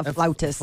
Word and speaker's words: flautist: So she's a, um, how do you say it, oh. flautist? Yes, a flautist.